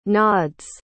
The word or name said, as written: nod's